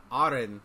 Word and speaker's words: Eren: Arin, that's how you pronounce it, actually,